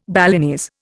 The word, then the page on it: Balinese: Indian